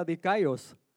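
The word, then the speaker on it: dicaios (δίκαιος): La justificación es una palabra griega que se se pronuncia dicaios y quiere decir inocente.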